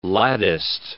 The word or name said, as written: latticed